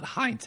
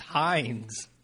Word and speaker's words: Heinz: You got me saying Heinz. It's not Heinz. It's Heinz. There's no T in there.